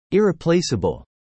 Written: irreplaceable

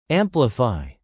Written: amplify

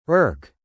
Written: irk

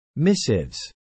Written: missives

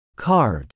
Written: carved

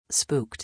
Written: spooked

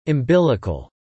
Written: umbilical

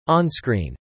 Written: onscreen

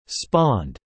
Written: spawned

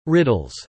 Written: riddles

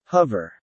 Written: hover

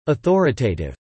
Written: authoritative

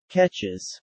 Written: catches

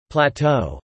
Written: Plateau

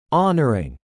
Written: honoring

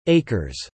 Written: acres

